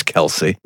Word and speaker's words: Kelce: Helen, it's Travis Kelce.